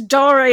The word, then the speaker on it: Dare: Okay, no, I used to pronounce it Ludum Dare, and then, like, old school Ludum Dare people were like, oh, this 12-year-old boy is calling it Ludum Dare, it's Dare, it's Latin, it means game play or something.